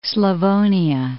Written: Slavonia

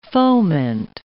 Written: foment

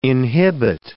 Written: inhibit

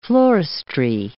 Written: floristry

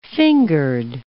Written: fingered

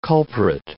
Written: culprit

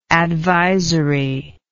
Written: advisorily

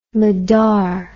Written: mudar

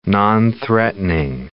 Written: nonthreatening

